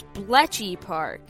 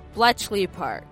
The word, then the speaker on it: Bletchley Park: I have accidentally been mispronouncing Bletchley Park as Bletchy Park, which must be confusing, and hopefully I didn't spread too much misinformation. From now on, I will be careful and call it Bletchley Park.